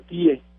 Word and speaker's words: TIE: La TIE, claro que, que no, está mal pronunciado, no es la tía, es la TIE.